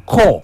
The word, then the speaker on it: KOHH: His name is Ko. It's spelled K-O-H-H. And he calls this song My Last Heartbreak. He features his boy Luta. You will like this one, because I did. – Ko